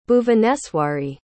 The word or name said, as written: bhuvaneswari